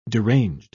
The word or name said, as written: deranged